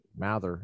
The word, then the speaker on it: Mather: spelled the same way, but we've always pronounced it Mather, M-A-T-H-E-R. Kind of like, I don't know if you are familiar with the Puritans, Cotton Mather, Increase Mather, Richard Mather.